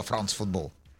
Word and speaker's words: Франс Футбол: Итак, журнал «Франс Футбол». А ну скажи красиво, «Франс Футбол». – Вот правильно произносишь, «Франс Футбол», как оно есть.